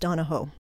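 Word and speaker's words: O'Donohue: It looks like Donahue. Apparently in Ireland, it's pronounced Donohoe.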